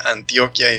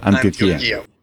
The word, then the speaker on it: Antioquía: la iglesia de Antioquía. Si lo dije bien, siempre me da nervios que diga Antioquia y digan que es Ignacio el Paisa. – No, hermano, sonó perfecto. Antioquía.